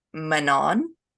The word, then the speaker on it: Manon: It's actually supposed to be pronounced Manon.